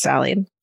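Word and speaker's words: Sali: My great grandmother, Sally, it's S-A-L-I, but then she tells me it's pronounced Sally.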